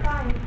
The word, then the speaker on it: Keynes: Your second one is pronounced kind.